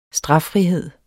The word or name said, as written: straffrihed